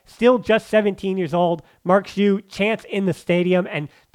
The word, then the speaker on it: Marc Guiu: Marc Guiu on for Fermin Lopez, and I was told it was Mark G, so this is a pronunciation correction. It is Marc Guiu moving forward. That is the Catalan pronunciation, so that's what we're going with.